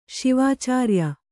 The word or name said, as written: ಶಿವಾಚಾರ್ಯ